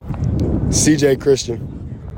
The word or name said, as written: CJ CHRISTIAN